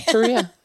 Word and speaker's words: Turiya: I'm going to mispronounce it. Turiya. – You're not far. Yeah. Okay. Turiya. Yeah.